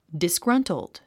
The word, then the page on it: disgruntled: disɡrʌ́ntld; ディスグラントゥド